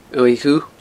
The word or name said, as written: uaimh dhubh